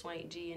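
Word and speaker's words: $WVNKG: But if you just want to look me up by hand, my artist name is SwankG, and it is spelled with a dollar sign and a V instead of an A.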